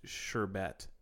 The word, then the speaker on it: sherbet: It's technically pronounced sherbet. Man, I always put the R in it. It's sherbet.